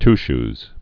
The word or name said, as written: goody two-shoes